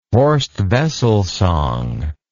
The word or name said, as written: Horst Wessel song